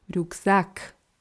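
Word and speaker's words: рюкзак: Ja, wichtig ist, die Betonung im Russischen ist anders auf der zweiten Silbe. Rucksack.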